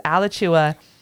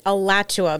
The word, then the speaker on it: Alachua: I'm pretty sure it's Alachua, but Alachua. Either way, you say potato, I say potato. – Alachua. Yeah. Well, so it's A-L-A-C-H-U-A. That makes more sense, the way we pronounce some of these cities in Florida. Alachua sounds probably closer.